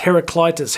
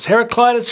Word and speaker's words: Heraclitus: All right, I should say that some of the purists who watch this channel would say, it's pronounced Heraclitus. Heraclitus, Heraclitus, follow your heart, friends, whatever sounds better.